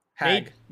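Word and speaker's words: Haag: Uh, how do you say that? Haig? Hog? Or uh, whatever. – Uh, Hag. Nadeshot.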